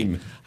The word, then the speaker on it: heme: Heme. – Sorry. Heme. I always mispronounce it.